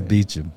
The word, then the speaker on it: Beauchamp: It's Beecham. – Beecham. – It looks like Beauchamp, don't it? – Probably.